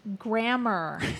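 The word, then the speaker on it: Gremmar: It's spelled with an E. G-R-E-M-M-A-R. – Pronounced Grammar.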